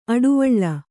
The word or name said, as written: ಅಡುವಳ್ಳ